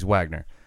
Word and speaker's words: Waggener: It's just pronounced Waggener, though. But okay. My apologies, Waggener.